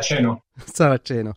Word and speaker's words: Saraceno: Et puis, euh, pour nous accompagner dans notre réflexion, et eh bien, Francesco Serrazzeno, je le prononce bien, Francesco, bonjour. Bonjour, Sarah Saraceno.